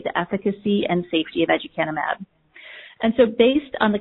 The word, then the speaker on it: aducanumab: Aducanumab is an investigational human monoclonal antibody studied for the treatment of Alzheimer's disease.